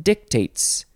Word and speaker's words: dictate: Or you can say, you can emphasize the other syllable, dictate.